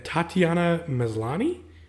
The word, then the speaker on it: Tatiana Maslany: But kind of before we get into that, Tatiana Maslany, do you think I said that right? – Sure.